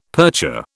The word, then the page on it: percher: British English